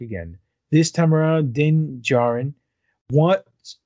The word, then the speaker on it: Djarin: It's Din Djarin. The, the D is silent.